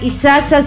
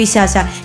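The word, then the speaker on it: Isaza: Si ustedes me quieren seguir en las redes sociales, en el Spotify, descargar mis canciones, de esta manera se escribe Isasa. Cristian, te cuento que mi apellido es I latina, S-A-Z-A.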